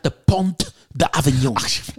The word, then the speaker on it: Pont: Pont. – Und das T spricht man nicht aus.